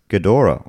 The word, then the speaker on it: Ghidorah: Pretty sure it's pronounced Ghidorah.